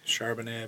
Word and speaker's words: Charbonnet: Charbonnet, is that how you say his name? – Charbonnet, I believe.